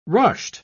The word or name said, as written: rushed